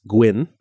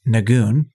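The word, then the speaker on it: Nguyen: N-G-U-Y-E-N. I believe it's pronounced Gwyn. I may be wrong. And Johnny's trying to pronounce it. I don't think he's having much success. – No. He pronounces it as Nagoon, which, okay.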